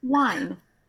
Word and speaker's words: whine: Saying, wine, W-H-I-N-E. You don't pronounce the H, right?